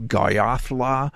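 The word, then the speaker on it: Goyahkla: His real name is, it's hard to pronounce. It's in Apache and it's sort of Goyothla. Spelled very much differently, but it means one who yawns.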